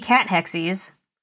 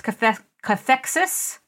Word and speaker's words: cathexis: It's not, because I really thought it was going to be cat hexies. But I listened to it online. It's cathexis.